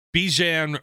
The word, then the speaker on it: Bijan: Tyree Wilson to the Raiders, Bijan Robinson I believe I've been saying it wrong. I think it's like mustard.